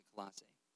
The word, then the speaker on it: Colossae: Now, Colossians is important because while Paul was imprisoned for preaching the gospel, he wrote this letter to the church in Colossae, however you want to pronounce that word. I pronounce it Colossae.